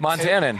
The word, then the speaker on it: Montanan: Is Montanan the correct? – Montanan.